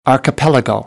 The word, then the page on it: archipelago: American English